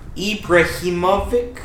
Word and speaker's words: Ibrahimovic: Ibra Ibrahimovic.